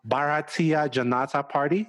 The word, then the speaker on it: Bharatiya Janata Party: Bharatiya Janata Party, is that it? Did I get that correctly? Okay. – Yeah.